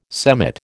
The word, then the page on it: semmit: American English